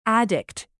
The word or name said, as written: addict